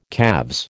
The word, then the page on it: calves: American English